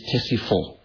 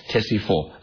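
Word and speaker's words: Ctesiphon: Madain is Tesifor. Studied as spelled with a C and a T. The C is silent. Tesifor, Madain.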